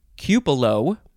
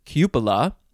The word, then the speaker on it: cupola: For instance, cupolo instead of cupola. That one is not so accepted because typically the A at the end of cupola is never pronounced as an O, but some people like to do it that way, I guess.